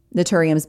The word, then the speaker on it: Naturium: Naturium is spelled N-A-T-U-R-I-U-M, Naturium.